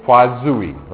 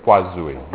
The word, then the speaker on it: Poiseuille: Poiseuille, right? Poiseuille, which is French. It's not spelled anything like it's pronounced, especially the way I pronounce it. It's probably not very French at all.